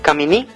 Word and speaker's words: Caminí: Otra curiosidad, el título del disco, Caminí, con acento en la I final.